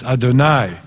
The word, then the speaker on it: Adonai: And the Hebrew, of course, as most of you know, is odd because it's written Yud-Heh-Vav-Heh, but Jews always pronounce it as Adonai, as though it were a different word, which means derived from the Hebrew word master, a different name for God.